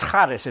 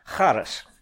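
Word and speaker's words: charis (χάρις): The word is charis, in case you wanted to write it down. Charis. Would you like me to spell it for you? Okay, C-H-A-R-I-S, charis.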